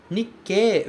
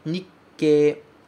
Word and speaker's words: Nikkei: Nikkei, veja que existe um tsu pequeno ali. O segundo caractere é um tsu pequeno. Então, você vai pronunciar esse ke com uma pequena pausa antes, certo? Nikkei, Nikkei, Nikkei.